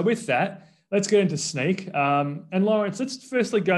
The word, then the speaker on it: Snyk: It is pronounced Snyk and it stands for, so now you know, think of Snyk, Snyk. Some people in Europe call it Snyk, but yeah.